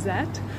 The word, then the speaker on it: Z: But then also, Z is pronounced Zet.